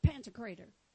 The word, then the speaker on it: Pantocrator: Everybody say it, Pantocrator. Now that's how you say it in Texan. Pantocrator. I'm sure Greek probably sounds all different. We messed it up real bad. But it looks like Panther Crater, doesn't it?